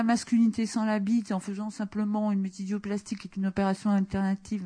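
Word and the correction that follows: métaïdoïoplastie: Métaïdoplastie, excusez-moi, j'ai écorché.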